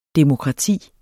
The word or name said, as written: DEMOKRATI